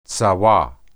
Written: tsawah

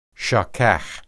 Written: shakah

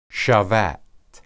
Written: shabat

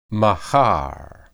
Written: mahar

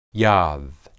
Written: yad

